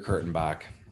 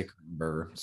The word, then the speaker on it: Kurtenbach: Dieter Kurtenbach. I want to say Kurtenberger. Sorry, Dieter.